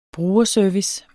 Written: brugerservice

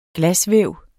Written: glasvæv

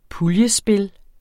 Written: puljespil

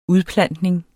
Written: udplantning

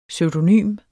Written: pseudonym

